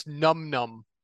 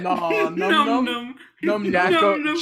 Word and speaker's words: No Name: They are Qbert, a slime folk ranger, Discreet, a tiefling sorcerer warlock, Ouroboros, dragonborn druid rogue, and No Name, pronounced Num Num. Nunum. – No, I love it. – Num Num.